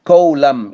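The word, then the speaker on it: column: طبعا بقى أي كلمة عندنا تنتهي بـ MN ما ننطقش الـ N، كولم يعني عمود، religious song بيقولوا عليها ترنيمة عندهم اللي هي هم، ما ننطقش الـ N، كوندم ان.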